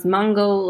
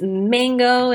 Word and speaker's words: mango: when I used to pronounce Mongo like Mongo, and I used to get made fun of like, oh my gosh, you have an accent, it's mango, and it's like an exotic fruit.